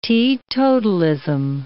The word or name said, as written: teetotalism